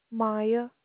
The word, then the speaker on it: Miya: Maya. – How you spell that, please? – M-I-Y-A.